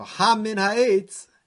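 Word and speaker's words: hamin ha'etz (הֲמִן הָעֵץ): So hamin ha'etz, hey Memnon, is Haman, right? It's actually pronounced hamin ha'etz, from that tree that I told you not to eat.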